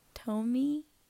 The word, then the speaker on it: Tuomi: After the 9 years that he killed the guy in his home, in September of 1987, Dahmer picked up a 26-year-old named Stephen Tomi. I think I'm saying that right. T-U-O-M-I.